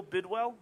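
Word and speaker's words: Bidwill: But quick and most importantly, the passing of Bill Bidwell, that's how I pronounce it, right? – Yeah.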